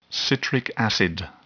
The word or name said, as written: citric acid